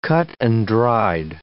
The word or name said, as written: cut-and-dried